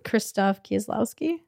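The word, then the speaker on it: Krzysztof Kieślowski: And just made me excited and more curious about delving into the films of I can't pronounce the director's name. What is it? – Krzysztof Kieslowski.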